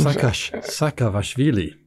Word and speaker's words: Saakashvili: He has a Georgian name that I can't pronounce, but it ends in Gashavili, like many Georgian names. – Saakashvili. – There we go.